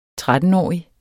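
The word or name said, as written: 13-årig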